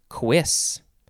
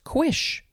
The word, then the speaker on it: cuisse: Last word for this episode is cuisse. C-U-I-S-S-E. Cuisse. You could also spell it, and there's a different pronunciation for this, C-U-I-S-H, which would be pronounced quish. Cuisse and quish.